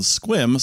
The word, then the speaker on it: Sequim: We're going to a similarly confusing place in a little bit called Squim, spelled Sequim.